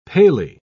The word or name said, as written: paly